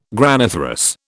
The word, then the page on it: graniferous: British English